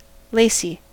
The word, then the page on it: lacy: American English